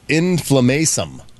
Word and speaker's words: inflammasome: In science news, researchers map out the structure of inflammasome. I said that right. Inflammasome, not inflammation.